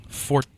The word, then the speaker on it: Forza: Forza, sorry. I keep pronouncing it wrong. Forza. – That's true. – It's like pizza, but yep. – for Forts.